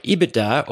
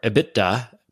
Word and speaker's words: EBITDA: Yeah, EBITDA, or EBITDA, pronounced both ways, I've heard, is earnings before interest, taxes, depreciation, and amortization.